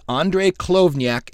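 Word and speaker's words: Andriy Khlyvnyuk: The singer of Boombox is Andrei Klovnyuk. Did I say that correct? – You did. – Yeah, we had YouTube videos. Here's how you pronounce it. Do it again. I believe it's Andrei Klovnyuk, and if I'm not on the money, I'm at least close.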